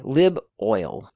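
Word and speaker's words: liboil: lib oil o-i-l oil say that as two syllables lib oil lib oil um,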